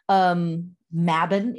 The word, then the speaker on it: Mabon: Mabon, it's pronounced here in America, Mabon a lot. Mabon is the correct pronunciation.